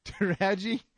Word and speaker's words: Taraji: Is it Tarji? Tarjai P. Henderson. All right. I'll stop poking. Taraji? P. Henson.